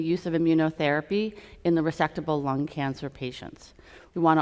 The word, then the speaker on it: resectable: resectable lung cancer.